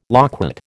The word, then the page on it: loquat: American English